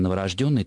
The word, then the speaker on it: новорожденный: Конечно, новорожденный, только так слово «рожденный» имеет во всех производных словах именно такое ударение: новорожденный, незаконнорожденный, мертворожденный и так далее.